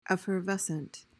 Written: effervescent

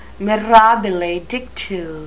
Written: mirabile dictu

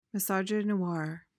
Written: misogynoir

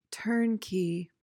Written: turnkey

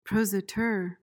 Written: prosateur

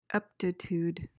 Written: eptitude

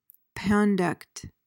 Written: pandect

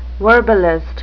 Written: verbalist

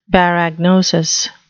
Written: baragnosis

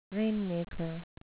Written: rainmaker

